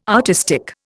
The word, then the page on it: artistic: Indian